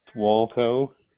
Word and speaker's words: Wolkow: Walco.